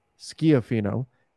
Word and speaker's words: Schifino: It would be Schiafino with the I sounding like the E's in feed, Schiafino, and the A like an apple and the O like the O in God, and the stronger sound is on the last I, so Schiafino.